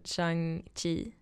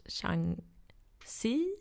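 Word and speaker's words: Shang-Chi: Jag gick och kollade på den nya Marvel-filmen, chang chi Eh, vilket tydligen uttalas Shang C, I think.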